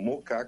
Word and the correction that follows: Moca: Café, aqui no Alto da 15, no Moca, perdão, pronúncia correta,